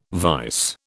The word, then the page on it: vise: British English